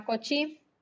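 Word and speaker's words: Cauchy: Y hay otro amiguito que se llama Cochi. Cochi. Cochí se escribe Cauchi.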